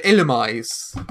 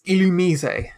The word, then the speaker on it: Illumise: I can't even, because most people would pronounce it Illumise. – Yeah. – Or Illumise or Illumise. But it's Illumise.